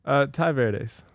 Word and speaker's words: Tai Verdes: And Ty, how do you pronounce it? – Ty Verdes. – Ty Verdes. – Yeah. – Yeah, I was saying Ty Verdes, but yeah. It's Ty Verdes.